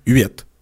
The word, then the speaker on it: Huet: Mais le goaler lui-même, le gardien, le cerbère, lui-même, il dit que son nom, ça se prononce Uet.